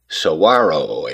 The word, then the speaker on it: saguaro: First, pronunciation, the go, is silent, sounding like a WH. It's said saguaro, a word originating from indigenous peoples of the Sonoran Desert, meaning giant tree-like cactus.